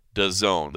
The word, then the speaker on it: DAZN: All right, so if you haven't heard, that's how it's pronounced, DAZN. The company is all caps, D-A-Z-N, but if you want to say it right, it is DAZN.